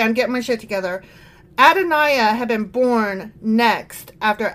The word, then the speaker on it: Adonijah: Adonijah, had, I keep saying his name wrong because the J is a Y. Uh, Adon- Adoniah.